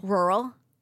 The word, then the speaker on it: rural: Number one, you're the only one I know who can pronounce the word rural. – Rural? – Well done.